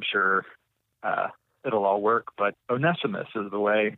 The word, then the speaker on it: Onesimus: And I'm going to go with Onesimus today as the proper pronunciation. Onesimus.